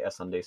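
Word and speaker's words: SSundee: Yeah, I'm pretty sure it's supposed to pronounce S-S-Undy. Because it's like a ship, like the SS, and then like undie, like underwear.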